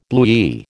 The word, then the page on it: bluey: American English